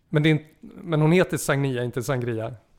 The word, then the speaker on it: Sagnia: Som, det ska uttalas Sagnia har jag lärt mig. – Jaha, jag tänkte Sagnia. – Ja, precis. Jag tror att hon själv sa någon gång att det ska rimma på Sangria.